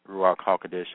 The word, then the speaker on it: Ruach HaKodesh: That's how you're going to stimulate the Ruach HaKodesh, or the Holy Spirit, by, and that's Ruach HaKodesh, that's in Hebrew, the Holy Spirit. That's how you pronounce it.